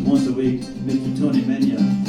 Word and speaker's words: Megna: Now, I learned much later that Megna, M-E-G-N-A, is pronounced Menya if you're Italian, but we didn't know any better.